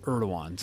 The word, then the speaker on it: Erdogan: I think I heard someone say Erdogan, maybe. But that's, we don't want to sound ignorant on this show, though it sometimes happens. It's pronounced Erdogan, so let's start there.